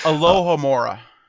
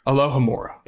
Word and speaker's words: Alohomora: I'm going to butcher this name, but Alohomora, I believe is how you're supposed to say it. – It's Alohomora. – Alohomora.